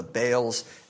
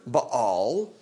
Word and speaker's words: Baal: The word Baal, which would be a closer to correct pronunciation, two syllables, Baal, usually when it's spelled out in English letters to try to carry the Hebrew into English, it'd be like B-A with an apostrophe and an A-L, so just see the two syllables in there.